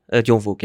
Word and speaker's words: John Verhoek: Ja, John Verhoek.